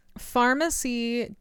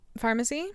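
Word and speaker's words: Farmacy: Look, it's spelled like pharmacy. – Yeah. – Okay, I got like a lip liner. – Pharmacy? It's spelled like pharmacy. – It's like spelled farm, like F-A.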